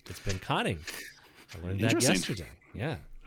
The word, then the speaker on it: Pinconning: Hey, that city town in Michigan that's spelled P-I-N-C-O-N-N-I-N-G. How do you pronounce that? – Wait, the spelled what? – P-I-N. – P-I-N. – C-O-N-N. – C-O-N-N. – I-N-G. – Pincon. Pinconing? Pinconing? – That's right.